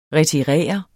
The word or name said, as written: retirere